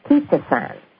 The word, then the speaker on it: chitosan: It is C-H-I-T-O-S-A-N, and it's pronounced ketosan. But it's C-H-I-T-O-S-A-N.